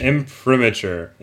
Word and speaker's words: imprimatur: Ah. Imprinture, imprimatur, imprinture. – Ah, no, I didn't know that. No, it's in, imprimatur. Imprimatur.